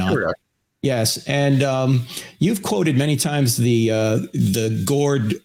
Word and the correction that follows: Borde-Guth-Vilenkin: or the I always get the name wrong. – The Gord Vilenkin. – Yeah. The Bord-Guth Vilenkin theorem.